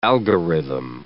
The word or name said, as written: algorithm